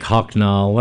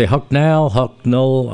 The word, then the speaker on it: Hucknall: And I say Hucknall, I'm not sure how the vowel's pronounced in the second syllable, but it's H-U-C-K, like Huck Finn, and N-A-L-L, not nail, but N-A-L-L, Farther Up the Road.